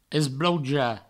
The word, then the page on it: esbloujar: esblouja; provençal